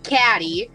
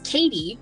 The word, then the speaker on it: Cady: Bro, the amount of times I've heard that fucking joke in my life, people, like, so she spells it C-A-D-Y, which is the worst fucking way to spell it. She gets called Caddy. I've gotten called Caddy so many times in my life because of this fucking movie. They're like, okay. Caddy? I'm like, it's pronounced Katie.